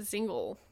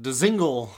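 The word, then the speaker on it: Dzingel: Cedric Paquette and Alex Galchenyuk were traded to the Carolina Hurricanes on Saturday, February 13th by the Ottawa Senators for Ryan Zingle. – I'm glad you didn't pronounce it DeZingle, because that's the way it's spelled.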